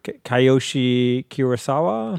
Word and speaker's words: Kiyoshi Kurosawa: This is directed by, do I try to pronounce this? Kiyoshi Kurosawa? – Yep. I think that's pretty good.